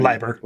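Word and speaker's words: Leiber: How do you pronounce his last name? – I know Lieber. – Lieber, okay.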